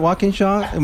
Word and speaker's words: Walkinshaw: Walkinshaw? – It's walk like the action, yeah. – In like the preposition, yeah. Shaw like Shawshank Redemption, walk in Shaw.